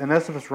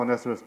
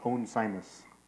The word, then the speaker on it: Onesimus: And I used to call Onesimus Onesimus. because in my understanding of grammar, if you have a word and you put an E at the end of it, whatever vowel is in the middle becomes long, right?